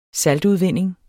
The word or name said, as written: saltudvinding